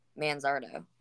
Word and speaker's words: Manzardo: That's not bad for Kyle Manizardo. Manzardo. That's how to pronounce it.